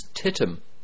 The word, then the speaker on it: titim: This TITIM Holdings, TITIM, is how you would pronounce that in Irish.